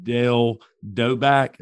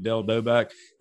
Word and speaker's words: Dale Doback: Number two, you had Brennan Huff and Dale Doback. Did I say that correctly? – Yes, sir. – You got Brennan Huff and Dale Doback.